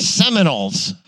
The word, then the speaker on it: Seminoles: The semen holes. Isn't that how you pronounce it? – Seminoles.